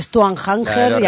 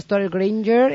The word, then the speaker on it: Stuart Granger: Y Stuart Hanger, y había que entender ahí de mi padre que se refería a Stuart Granger.